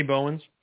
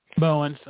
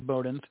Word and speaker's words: Bowens: I'm going to mispronounce it if I say it. – Anthony Bowens. – Bowens. I was going to say Bowdens.